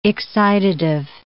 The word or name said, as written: excitative